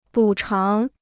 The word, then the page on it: 补偿: bǔ cháng